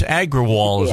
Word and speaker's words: Agrawal: I have no idea how to pronounce this name. He's Indian, so it's PARAGAGRAWAL. Agrawal? – Yeah, it's Agrawal, yes. I think is how he pronounces his last name.